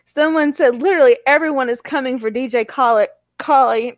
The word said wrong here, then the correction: Khaled: DJ Khaled. I'm sorry. I know how to say his name. DJ Khaled. Because he says it in every song.